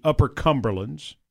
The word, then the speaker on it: Upper Cumberlands: I'm Mike Keith, joined by Titans radio legend Larry Stone, president and CEO of Stonecom, here in the Upper Cumberlands. Did I say that properly? – You said that properly.